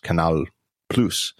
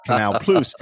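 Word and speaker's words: Canal+: And in French, apparently, it's pronounced Canal Plus. And I wrote as a little sort of joke that because it's French, it's pronounced plus, P-L-O-O-C-E, as my phonetic spelling for English people. And all these French readers are telling me that's not how it's pronounced at all.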